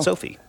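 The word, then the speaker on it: SoFi: Or if you want to just be a little bit more casual, Ron, you can just pronounce it Sophie. Right? – There you go. – Sophie.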